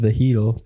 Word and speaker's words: vegetal: Yeah, I believe it's pronounced vegetal, but you guys do you.